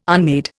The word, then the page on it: unmade: Indian